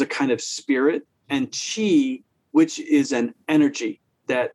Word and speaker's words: qi: What is your understanding of, I think it's pronounced qi, it's Q-I is how it's spelled.